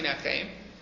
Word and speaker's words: Yinachem: I mean, the pasuk is actually the way it's pronounced is Yinachem.